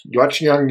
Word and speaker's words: Watchung: Over the course of his career, he served for nearly 20 years in pastorates at Wilson Memorial Union Church in Watchung, New Jersey. Did I say that right, David? – Yes. – Watchung, New Jersey, at First Presbyterian Church in Irving, Texas, and at Westminster Presbyterian Church in Galveston, Texas.